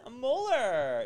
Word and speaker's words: Muller: I have my guest here, Ben Muller. Is that how you say your last name correctly? – Yeah, it's Muller. Lots of people say Mueller, like Bueller.